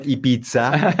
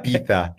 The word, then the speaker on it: Ibiza: It only took me 2 weeks to pronounce Ibiza correctly, because the Z is in Spanish. – So not Ibiza, Ibiza. – Ibiza. So we go to Ibiza, and it's a very interesting experience.